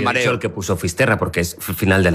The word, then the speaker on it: Fisterra: Entonces llegó a Finisterre, ¿se dice así? – Fisterra.